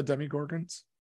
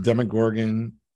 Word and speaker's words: Demogorgon: Do you know about the demigorgons? Demigorgon. – I know what a demigorgon is. I have not been watching Stranger Things. It's not, our television doesn't get that, as we say. – Demogorgon.